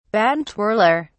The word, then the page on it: baton twirler: British English